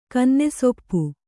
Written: ಕನ್ನೆಸೊಪ್ಪು

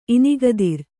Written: ಇನಿಗದಿರ್

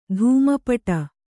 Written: ಧೂಮಪಟ

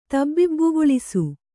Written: ತಬ್ಬಿಬ್ಬುಗೊಳಿಸು

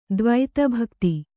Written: ದ್ವೈತಭಕ್ತಿ

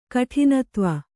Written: ಕಠಿನತ್ವ

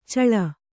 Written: ಚೆಳ್ಳೆ

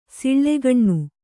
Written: ಸುಲಗ್ನ

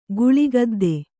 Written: ಗಾಳಿಗುದ್ದು

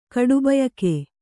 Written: ಕಡುಬಯಕೆ

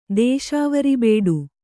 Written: ದೇಶಾವರಬೇಡು

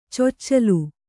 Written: ಚೊಚ್ಚಿಲು

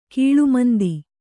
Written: ಗಾಳುಮಂದಿ

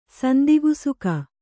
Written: ಸಂದಿಗುಸುಕ

